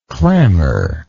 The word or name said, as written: clamorist